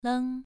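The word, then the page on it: leng: tone 2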